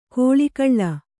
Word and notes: ಕೋಳಿಕಳ್ಳ: kōḷi kaḷḷa

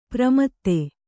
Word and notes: ಪ್ರಮದೆ: Kannada

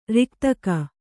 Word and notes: ರೇಖೆದೆಗೆ: Kannada

